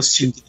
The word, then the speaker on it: Sting: e o Sting, né? Que é o baixista e vocalista. Falei certo, né? Sting, nem né? lembro o nome do cara, né? Isso, é o Sting.